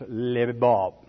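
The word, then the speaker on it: lebab: And I'm sure most of you know those, but in case you don't, L-E-B, leb, pronounced leb, leba, L-E-B, B-A-B, okay, spelled, pronounced lebob, okay, that's heart in the Hebrew tongue.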